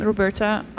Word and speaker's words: Roberta: Yeah, pitonneux is P-I-T-O-N-N-E-U-X. R-O-N-N-E-U-X. And my name, Roberta, R-O-B-E-R-T-A.